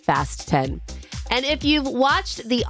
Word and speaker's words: Fast X: And a lot of people say Fast X, but the director says no, it's pronounced Fast 10, as in fasten your seatbelts.